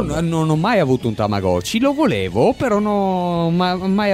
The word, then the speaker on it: Tamagotchi: E simulava la vita di un qualcosa, di un Tamagotchi, appunto. – Sì, un Tamagotchi, esatto.